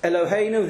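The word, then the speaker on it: Eloheinu (אֱלֹהֵינוּ): Eloheinu, the E is also, it's not E. It's a chatav segel, eh. And the stress is on the hey.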